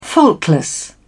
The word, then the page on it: faultless: British English